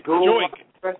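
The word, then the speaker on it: Joique: The guy that everybody stole off the waiver wire this week was Jaquan Bell. He's still Joik. Get his name right. It's Joik. Joik Bell.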